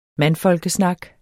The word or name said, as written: mandfolkesnak